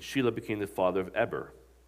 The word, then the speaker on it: Eber: Also to Shem, the father of all the children of Eber, it's not Eber, it's pronounced Eber, I learned, and the older brother of Japheth, children were born.